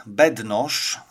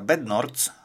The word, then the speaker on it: Bednorz: I jednym z zaproszonych gości był pan Georg Bednosz, albo jak kto woli, jak słyszałem również wymowę tego nazwiska, Bednorz, z anglojęzyczni uczestnicy tak wymawiali to nazwisko.